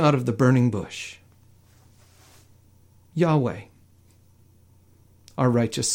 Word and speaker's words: Yahweh: It was the four letters that possibly were pronounced Yahweh.